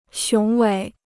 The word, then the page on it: 雄伟: xióng wěi